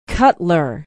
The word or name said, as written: Cutler